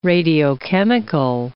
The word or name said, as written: radiochemical